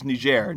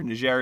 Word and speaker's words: Niger: I might have just misheard you, but yeah, it is Niger. Niger is the correct answer.